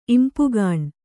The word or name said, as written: ಇಂಪುಗಾಣ್